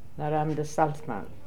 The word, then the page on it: Salzmann: Mme de